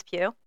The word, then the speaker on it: Pugh: Don't Worry, Darling with Harry Styles and Florence Pugh.